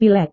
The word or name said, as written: pilek